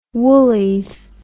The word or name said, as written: woolies